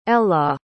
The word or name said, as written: Ela